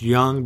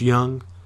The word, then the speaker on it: Jung: And for those of you who maybe didn't notice the logo or are not familiar, the last name is pronounced Young, but it's spelled J-U-N-G. I've never really had people get that correct on the first try. It's usually Jung, which is what I was called in college. And I certainly understand, but it is pronounced Young, spelled with a J.